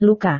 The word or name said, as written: luka